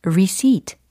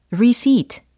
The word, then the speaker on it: receipt: R-E-C-E-I-P-T 라고 해서, 뭘까요? r e 리 i 프트가 아니라 receipt, receipt, receipt.